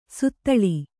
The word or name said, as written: ಸುತ್ತಳಿ